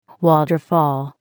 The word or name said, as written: waterfall